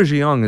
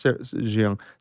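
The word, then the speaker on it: Jeong: They hired a woman, I think her is pronounced Jiang.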